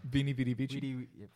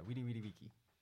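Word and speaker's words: veni, vidi, vici: Vini, vidi, vigi. – Vini, vidi, vici. – Yeah, weedy, weedy, weegy. In the classical Latin pronunciation.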